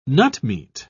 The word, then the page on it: nutmeat: British English